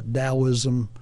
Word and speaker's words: Taoism: Taoism, pronounced with a D but spelled with a T. – Yeah, I never understood that. Yeah, Taoism.